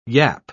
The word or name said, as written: yap